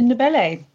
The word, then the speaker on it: Ndebele: Ndebele.